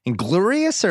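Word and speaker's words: Inglourious: And to round things out on the first pot of the week, at 1:38:45, a discussion about how to pronounce Inglorious Bastards. – Inglorious or Inglorious? – Inglorious. – Spelled Inglorious. – Is it really? – Well, it's got a weird spelling. Inglorious Bastards. – Bill caught up on the U.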